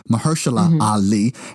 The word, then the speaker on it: Mahershala Ali: And Mahershala Ali. I hope I didn't slaughter his name. Mahershala Ali is how you pronounce it.